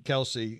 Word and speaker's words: Kelce: Kelce is talking about he thinks they're struggling because there's a lack of accountability.